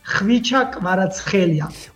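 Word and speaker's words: Khvicha Kvaratskhelia: In my language is like Hvicha Kvaratskhelia. – O Kvaratskhelia.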